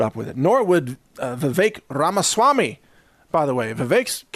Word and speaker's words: Vivek: Rhymes with cake. That's what his press spokesperson told me during an interview. Vivek rhymes with cake.